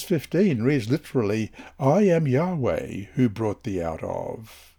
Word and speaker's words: Yahweh: When God first entered into a covenant relationship with Abram, he made himself known to the patriarch under the name Yahweh. That's capital Y, capital H, capital W, capital H, YHWH, pronounced Yahweh,